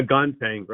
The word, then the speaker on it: Gontang: Am I pronouncing that right, Ozzie? – Yeah, Gontang, right.